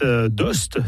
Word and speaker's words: d'Aoste: D'Ost, c'est comme ça qu'on doit prononcer, jongle entre tradition et modernité grâce au savoir-faire de ses artisans.